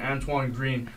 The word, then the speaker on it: Antoine Green: Drake May is obviously back, but he's losing his two best wide receivers in Josh Downs and Antoine Green. That's how you say his name.